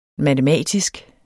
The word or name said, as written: matematisk